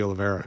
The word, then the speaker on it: Oliveira: Oliveira could just be, and I finally said it right, by the way, Brady Oliveira.